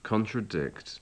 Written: contradict

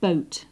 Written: boat

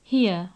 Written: here

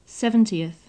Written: seventieth

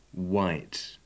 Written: white

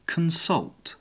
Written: consult

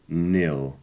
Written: nil

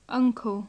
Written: uncle

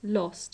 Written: lost

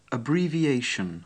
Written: abbreviation

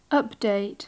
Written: update

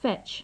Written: fetch

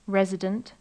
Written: resident

